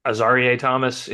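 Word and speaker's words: Azareye'h Thomas: Because the two drives they scored on, the field goal drive was actually probably should have been stopped by the Azarie Thomas interception. Did I get the name right? I was scared going into it. Great. Perfect.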